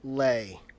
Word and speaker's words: Lay: It's Jeremy Lay. – Jeremy Lay. I'm sorry. Yeah, I will mispronounce things. – Yeah, in the interview, I asked her, she was like, it's Lay, so you know.